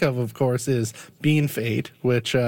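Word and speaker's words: Bienfait: and they're shocked that it's not pronounced BFA because that's what it looks like.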